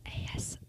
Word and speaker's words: ASMR: I gotta redo that. I pronounced it wrong, and it's only four letters. ASMR.